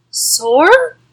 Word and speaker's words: Saor: Yeah, like that band from Scotland. I like S A O R. Sore.